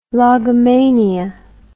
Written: logomania

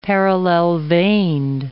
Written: parallel-veined

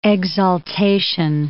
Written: exaltation

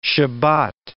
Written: Shabbat

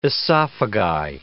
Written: esophagi

